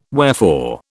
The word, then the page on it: wherefore: British English